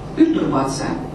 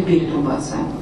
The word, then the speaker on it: пертурбация: Пертурбары произносятся именно так. Пертурбация, а не перетурбация.